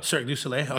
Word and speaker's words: Cirque du Soleil: Cirque du Soleil. I could never say it correctly. – You got it though. – Cirque du Soleil.